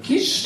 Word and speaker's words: Kiss: Aber da es ein ungarischer Name ist, wird er, glaube ich, Kisch ausgesprochen, soweit ich weiß.